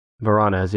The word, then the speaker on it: Voronezh: Number four, the Varonezh. It's spelled V-O-R-O-N-E-Z-H. Varonezh incident, number four. – Varonezh? – Varonezh, yeah.